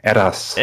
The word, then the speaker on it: Aeras: Habe ich das richtig ausgesprochen, Aeras? Ich würde es wahrscheinlich wie ein Ä, Eras. – Eras. So in der Richtung.